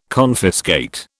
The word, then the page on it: confiscate: British English